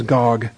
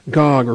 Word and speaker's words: Gog: All right, word of the Lord came to me saying, Son of man, set your face towards Gog. That's a personal name. Gog or Gog, make it a long O, of the land of Magog, the prince of Rosh, Meshech, and Tubal, or the chief prince of Meshech and Tubal.